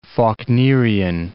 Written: Faulknerian

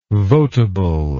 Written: voteable

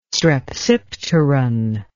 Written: strepsipteron

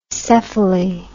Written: cephaly

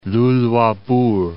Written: Luluabourg

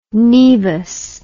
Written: naevus

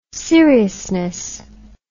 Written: seriousness

